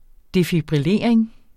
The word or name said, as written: defibrillering